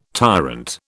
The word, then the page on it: tyrant: British English